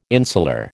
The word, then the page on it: insular: American English